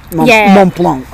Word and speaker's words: Mont Blanc: So it's basically Ultra Trail Mount Blanc. Mont Blanc.